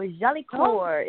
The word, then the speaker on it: Jolicoeur: Hey, it's Jolicoeur. Jolicoeur is a French name that means beautiful heart. So Jolicoeur, if you have a little French accent, but just Jolicoeur is fine.